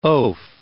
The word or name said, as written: oaf